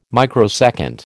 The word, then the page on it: microsecond: American English